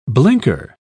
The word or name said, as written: blinker